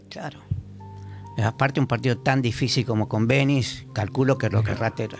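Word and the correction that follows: Benín: Con acento en la I. – Benin.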